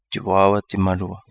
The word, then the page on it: Di Waawa Di Malua: Kapingamarangi